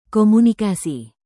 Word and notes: komunikasi: Indonesian

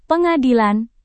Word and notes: pengadilan: Indonesian

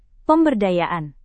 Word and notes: pemberdayaan: Indonesian

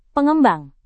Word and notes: pengembang: indonesian

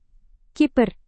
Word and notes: kiper: indonesian

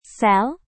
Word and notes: sel: Indonesian

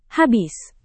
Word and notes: habis: Indonesian

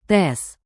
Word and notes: tes: Indonesian

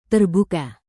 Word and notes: terbuka: indonesian

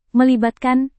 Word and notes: melibatkan: Indonesian

